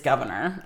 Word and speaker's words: Gouverneur: But his first name really is, it's spelled a little different, but it's Governor. Like, that's Governor Morris.